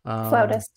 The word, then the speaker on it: flautist: She is a flutist. I don't know if I said that correctly. – Flautist. – Flautist. Okay, so she's a flautist,